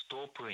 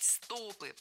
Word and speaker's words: стопы: Ну, прежде всего, вы правильно заметили, это упражнение на ноги, на стопы, на икроножные мышцы, но зажимать колени, ноги, стопы не рекомендуется. – А еще не рекомендуется говорить «стопы», потому что правильно «стопы». Это слово всегда имеет ударение на окончании во всех формах.